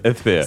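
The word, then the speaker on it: Өфө: А, кстати, Уфу называют три шурупа, потому что э, Эфе, ну, по-башкирски Уфа читается Эфе.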